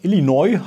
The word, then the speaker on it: Illinois: Oder Illinois, habt ihr gesagt, spricht man das aus, Illinois.